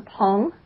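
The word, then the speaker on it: Phang: It's a Pong. It's P-H-A-N-G, but it's not pronounced Fang, even though that's kind of how it looks.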